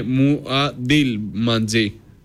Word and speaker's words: Μουαντιλμαντζή: Είναι μουαντιλμαντζή. Μουαντιλμαντζή. – Αμουαντιλμαντζή. – Δεν υπάρχει σα. Είναι μουαντιλμαντζή. – Μουαντιλμαντζή. – Μουαντιλμαντζή, ναι. Μουαντιλμαντζή. Στο γιο δεν τελευταίο τονίζεται, ναι.